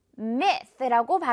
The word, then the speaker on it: myth: 또는 사람들이 가지고 있는 잘못된 믿음을 가리키는 단어인데 Y가 있는데 마이트가 아니라 myth라고 발음이 됩니다.